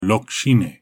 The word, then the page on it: Lok-shineh: Galindri